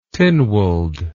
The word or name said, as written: Tynwald